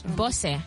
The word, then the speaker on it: Bosse: Am I saying it right? Bose? – Bosse. – Yeah, Bosse. Bosse. Yes.